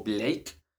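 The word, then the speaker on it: Blakw: Soy Diego Oviedo igual de repente me caga mi nombre, entonces pueden seguirme en Instagram como arroba Diego Blake, B-L-A-K-W. B-L-A-K-W, sí, lo dije bien.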